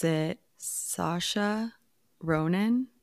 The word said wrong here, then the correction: Saoirse: Oh, Saoirse you know? Ronan? – Saoirse, yes.